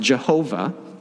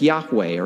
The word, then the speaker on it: Yahweh: That word, that name, historically has been pronounced Jehovah, and so you'll see that still today in some Christian songs and hymns. But we're convinced that it was probably more accurately pronounced as Yahweh or something like that, Yahweh, Jehovah, God's personal name.